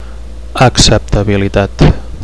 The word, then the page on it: acceptabilitat: Catalan